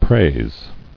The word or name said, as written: Praised